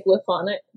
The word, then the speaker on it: Glyphonic: I say Glyphonic, but like hieroglyph.